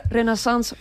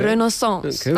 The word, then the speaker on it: Renaissance: Entonces hoy quería hablar sobre la Renaissance de Disney, o como diríamos en español, la era del renacimiento de Disney, pero como que decir Renaissance mola más, ¿no? – Hombre, queda muy bien. Qué bien pronunciado, ¿eh? – Renaissance.